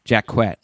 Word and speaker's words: Jacquette: Jacquette. – Oh, okay. – She strongly rejects the French pronunciation.